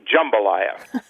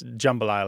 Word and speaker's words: jambalaya: when people do say jambalaya, it's a super short A. It's jambalaya like that.